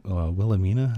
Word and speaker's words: Wilhelmina: Queen Wilhelmina, is that how you say her name? – Yeah.